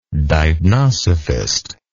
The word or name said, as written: deipnosophist